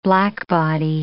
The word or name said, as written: blackbody